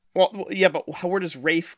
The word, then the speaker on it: Ralph: But to pronounce the name Ralph as Rafe, what's going on here? – Well, his dad is called also Ralph, but he also goes by Ranulph. – Okay, but is this a British pronunciation of the name Ralph that I don't know? – Yes, it is.